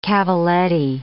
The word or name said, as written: cavalletti